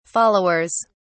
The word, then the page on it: followers: British English